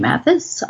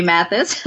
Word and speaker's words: Mathis: Mathis, author Susan G. Mathis. Twitter is at Susan G. Mathis. Just find me at Susan G. Mathis. – And your last name is spelled M-A-T-H-I-S.